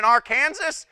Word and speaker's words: Arkansas: You live in Arkansas? You're arrested and they take you to jail. They have a stipulation that you must include all three syllables and the S is silent.